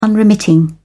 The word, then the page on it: unremitting: British English